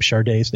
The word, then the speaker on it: Sade: But it turned out her name is spelled, for the kids out there who don't know these things, her name, Sade's name, is spelled S-A-D-E. As in the Marquis de, except it's pronounced Sade. And if you've heard her, she sounds like a Sade, not Assad.